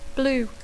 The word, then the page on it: blue: Catalan speaker; intermed